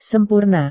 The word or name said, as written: sempurna